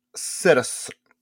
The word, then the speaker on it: Ketos: I call it Kedis. – Call it a what? – Cetus or Kedis. – Kedis? – It's spelled Kedis, but it's pronounced Cetus or Cetus. Cetus. It's spelled Ketos.